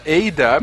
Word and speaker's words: Ada: Queridos, primeiro, pronúncia correta, Ada, Eida. imagino que Eida, né? Eu falo Ada porque sim. É, você fala Ada porque é portuguesou, mas o certo é Ida.